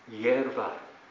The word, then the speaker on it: イエルバール: エルバール、もう少し正確に発音しますと、イエルバールという名は、バールが自らを大きくする、あるいはバールは争うという意味です。